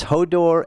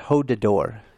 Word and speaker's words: Hodor: It's Hodor and not Hodador.